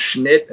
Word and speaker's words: Smit: who I guess his name is pronounced Schmidt. And it's, you know, spelled S-M-I-T, but they're calling him Schmidt.